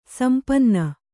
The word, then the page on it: ಸಂಪನ್ನ: sampanna